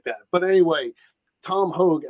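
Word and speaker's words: Tom Hoge: Now I'm going to go with a complete dark horse, even though I pronounce the guy's name Tom Hoag, but everybody says Tom Hoagie. But if you're from New Jersey and you are South Jersey where you grow up, that's not how you spell Hoagie.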